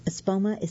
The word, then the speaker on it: Espoma: Espoma is spelled E-S-P as in Peter, O-M as in Mary, A.